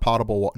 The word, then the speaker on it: potable: not potable, non-potable water. Is that how you say it? Non-potable. – Potable. Yeah, you have yeah.